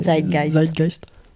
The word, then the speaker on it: Zeitgeist: Zeitgeist. – Soit à l'anglaise, soit à l'allemande, quoi. – Mmh. Je ne sais pas comment on le prononce à l'anglaise. À l'allemande. – Zeitgeist. – Zeitgeist.